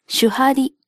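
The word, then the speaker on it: 守破離: 守る、破る、離れるという漢字 3 文字で主張りと読むのですが、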